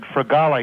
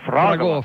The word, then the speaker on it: fragola: And the first time I said strawberry in Italian, I said fragola. He says, no, fragola.